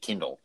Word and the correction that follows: Kendall: one of those reading tablets, like shortening the Kendall to Kindle.